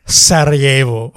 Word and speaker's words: Sarajevo: No, it's pronounced Sarajevo.